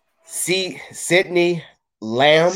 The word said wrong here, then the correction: CeeDee Lamb: CeeDee Lamb.